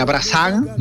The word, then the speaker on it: Brassens: La Penélope de Brassens, se escribe, ajá, Brassens, pero se pronunciaría Brassens, uh-huh.